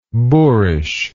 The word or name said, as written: boorishness